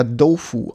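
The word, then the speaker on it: Dofu (豆腐): Im Chinesischen, das hätte ich vielleicht vorneweg sagen können, äh, dann mache ich es jetzt an dieser Stelle, wird das Tofu äh, weicher ausgesprochen. Da äh, wird es nämlich eher Dofu ausgenannt, äh, ausgesprochen.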